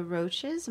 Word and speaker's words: Roches: Okay, so your next group is the Roaches. Am I pronouncing that correctly? – Mm-hmm.